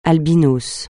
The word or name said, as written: albinos